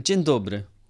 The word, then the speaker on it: Dzień dobry: Dzień dobry.